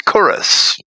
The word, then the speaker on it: Cyrus: This evening, it's part 44 of our commentary on Isaiah, and this is titled Cyrus, the Man of Gold, a name that was probably pronounced as Chorus. But we'll say Cyrus.